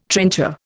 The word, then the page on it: trencher: Indian